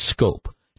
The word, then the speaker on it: Schoop: His name, it's spelled S-C-H-O-O-P, and his name is pronounced Scope. Now, how you get Scope from S-C-H-O-O-P is beyond me, but that's the case.